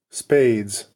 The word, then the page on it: spades: American English